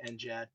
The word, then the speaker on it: NGAD: That's with a soft G, by the way. – It is. We pronounce it with a soft G. It's not next generation air dominance. It's next. The Air Force will say NGAD for their kind of next gen family systems, and we say NGAD.